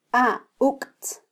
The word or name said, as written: auktz